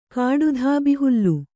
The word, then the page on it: ಕಾಡುದಬ್ಬೆಹುಲ್ಲು: kāḍu dabbe hullu